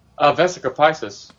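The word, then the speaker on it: Vesica Piscis: Several tracks from Visa Pieces? How do you say that? – Vesica Pisces.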